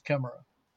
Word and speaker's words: Chimera: Is that how you pronounce mm-hmm. it? – I've said Chimera, but I mean, I'm sure. Chimera. – I think you're right. I think it's Chimera. And it's a strange monster, you know.